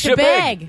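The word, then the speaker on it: Chebeague: I've never known how to pronounce that. – It's Chebig.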